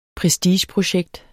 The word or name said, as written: prestigeprojekt